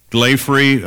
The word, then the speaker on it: Glaphre: Glaffery, I think the correct way of pronouncing it, her name was spelled G-L-A-P-H-R-E, Glaffery.